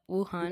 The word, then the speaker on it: Wuhan: Wuhan? – Yep, Wuhan. Yeah. Wuhan. Also, I'm back. I've been gone for a couple weeks. – Oh yeah. Finally. And but yeah, it started in Wuhan, China, and basically it's a zoonotic virus.